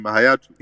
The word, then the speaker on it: Hayatou: Hayatou.